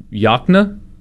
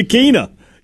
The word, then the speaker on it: Yokena: I'm going to spell it for you, and I want you to tell me how it's pronounced. – Okay. – Y-O-K-E-N-A. – Y-O-K... Yakna? – That's right! You said it correct! I thought you were going to say Yakina. Yes, Yakna.